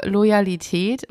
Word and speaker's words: Loyalität: Denk an Royal, denk an Royal und probier's. Okay, wie stehst du zu Loyalität?